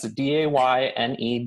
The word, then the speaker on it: Dayne: Sure, it's a uh, it's D A Y N E Dayne, and then the last name is Shuda S H U D A.